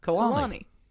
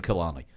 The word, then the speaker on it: Colani: C-O-L-A-N-I. – No, not Bortolano. – Kalani. – Unpronounceable by me. – As in the F1 team, Kalani.